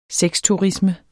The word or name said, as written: sexturisme